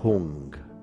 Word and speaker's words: Hung: Vamos a repetir la vibración de la sílaba Hung, H-U-N-G, y es una H con un sonido e de la H inglesa nos enfocamos, luz y vibración al mismo tiempo.